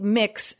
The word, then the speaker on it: Mx: For my ears, I think mix as a word, pronounced mix.